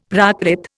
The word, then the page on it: Prakrit: Indian